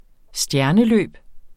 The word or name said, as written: stjerneløb